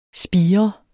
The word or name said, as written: spigre